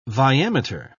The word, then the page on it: viameter: British English